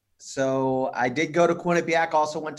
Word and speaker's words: Quinnipiac: So let's go back to, am I saying this right? Is it Quinnipiac University? – It's Quinnipiac. – Quinnipiac. All right.